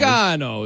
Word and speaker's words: Gargano: It's supposed to be, like, the old world says it, like, Gargano. Gargano is how you say it. Like, yeah. – In Italy, but, you know, whatever.